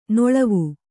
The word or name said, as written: ನೆಳವು